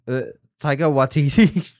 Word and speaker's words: Taika Waititi: Taiki, oh fuck, how do you say his Watson? Is that his last name? – Taika Waititi. – Oh, dude, I got that so wrong.